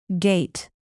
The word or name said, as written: gait